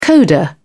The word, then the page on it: coda: British English